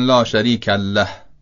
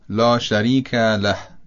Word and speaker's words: لا شریک له: یعنی لیه له رو با تشدید میخونن، به جای که بگن لا شریک له میچسبونن و با شدت و تشدید میگن لا شریک له. اینم طبیعتاً غلطه. پس تلفظ درستش لا شریک له خواهد بود.